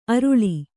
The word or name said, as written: ಅರುಳಿ